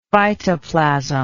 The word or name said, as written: phytoplasm